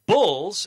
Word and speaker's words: Bulls: He is the head coach of the Buffalo Bulls. Emphasis on the U there, not the Bills, the Bulls.